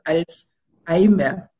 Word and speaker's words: Alzheimer: Porque este se descubre, bueno, lo descubre mi paisano, el doctor Alois Alzheimer, o eso lo puedo pronunciar también, eh, porque en alemán es, es distinto, no separamos los vocales y la H si es, no es muda, entonces por eso es Alzheimer.